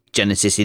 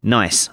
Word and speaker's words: gneiss: It's not Genesis in any way, shape, or form. I suppose it might even be pronounced gneiss. I think that's what you actually called it.